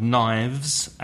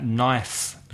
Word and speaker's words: knife: And remember, listeners, that knife with an F is the singular, K-N-I-F-E. So the K is silent, knife. But the plural has a V, knives. And then you get vibration in your throat, in your garganta. So it's knife and knives.